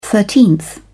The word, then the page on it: thirteenth: British English